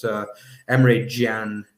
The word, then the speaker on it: Emre Can: It's that man, Emre Can, I should say. Yeah, it's Emre Can, as they call him here in Germany, and the correct way of pronouncing his name.